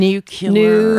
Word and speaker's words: nuclear: You know, it's the one that really gets to me, and it always makes me think of George W. Bush. – What? – Nuclear. – Oh, yeah. – Oh, nuclear. – Nuclear. – It's nuclear, folks.